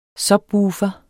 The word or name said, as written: subwoofer